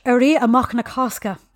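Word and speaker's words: Éirí Amach na Cásca: Here we go with the Irish. Éirí Amach na Cosca. And nice. I have to thank Dermot O'Mara for helping me with that pronunciation because I've wiped Irish from my memory.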